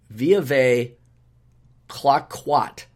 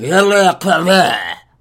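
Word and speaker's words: Veuve Clicquot: Advances, and I know it's spelled, here's how we would pronounce it with English. Advances by the House of Veuve Cliquot, okay?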